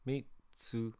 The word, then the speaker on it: Mitsuha: It's three syllables. Mitsuha.